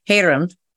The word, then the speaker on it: Hyerim: I wanted to ask you about, and thank you for pronouncing it correctly, Hyerim, is that right? – Yeah, that's right. Again, I don't speak Korean either, so it could be wrong, but that's how I pronounce it.